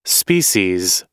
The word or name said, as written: species